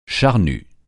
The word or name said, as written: charnu